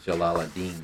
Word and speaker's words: Jalaluddin: I say it Jalaluddin.